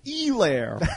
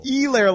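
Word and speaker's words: Helaire: By the way, yesterday – the pronunciation on TV on the Monday Night Football game, he kept saying Elair. – Yeah, I kept hearing that too. – Elair, like Eclair. But anyway, so silent H.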